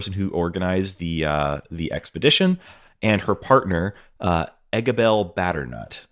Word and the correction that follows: Batternugget: or Batternugget. And Batternugget, yes.